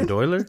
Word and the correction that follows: bandolier: How do you say it? It's a bandolier.